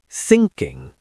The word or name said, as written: thinking